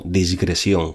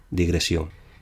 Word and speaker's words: digresión: Lo digo porque lo leo y lo escucho con excesiva frecuencia como disgresión, con S. Es incorrecto. Lo correcto es digresión.